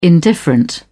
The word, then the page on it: indifferent: British English